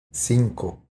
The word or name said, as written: Cinco